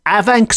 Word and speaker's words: Afanc: Afanc monster. And that's Afanc spelt A-F-A-N-C, with the F pronounced as a V in the Welsh language.